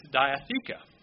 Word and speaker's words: diatheke: It's berith, if I'm pronouncing it right, B-E-R-I-T-H, and that's Hebrew. The other word is a Greek word, and it's diatheke, D-I-A-T-H-E-K-E.